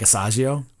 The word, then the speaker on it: Asiago: Yeah, that's Asiago cheese. – That's how the Italians on the Lower East Side.